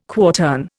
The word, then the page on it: quartern: Indian